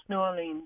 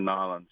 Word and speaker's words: New Orleans: Yeah, and then we're back at it again in January, going from Ohio down to New Orleans, How do I say it properly? – New Orleans. Or Nolens.